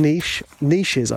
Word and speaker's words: niches: And there's a saying I hear the American entrepreneurs saying, and the reason I'm saying American is because we call them niches over here. So this saying doesn't work if you're saying it, pronouncing it like an English person.